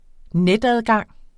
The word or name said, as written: netadgang